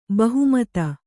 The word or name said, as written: ಬಹುಮತಿ